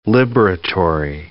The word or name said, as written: liberatory